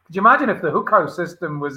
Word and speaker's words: Hukou: The Hukou system, yeah.